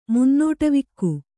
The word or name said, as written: ಮುನ್ನೋಟವಿಕ್ಕು